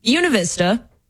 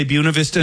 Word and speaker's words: Buena Vista: Oh, I am heading to Una Vista, which we say that place wrong. – Yeah, but they wanted us. They insisted that we say it wrong.